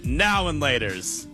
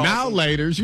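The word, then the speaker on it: Now and Later: I say now and laters. You say now laters, like it's all one word.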